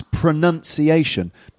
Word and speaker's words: pronunciation: And the subject is pronunciation, not pronunciation. So pronunciation is a common error.